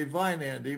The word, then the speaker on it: Weinandi: But so I suppose if you were going to pronounce it, it'd be Vine Andy. But we don't live in a German culture, so it comes out Wine Andy.